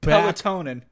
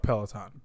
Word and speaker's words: Peloton: And we have to discuss the stationary bike company, which I still cannot pronounce, Peloton. – Even though I've been saying it to you for the past 2 weeks. – You know what's funny? – Pelotonin.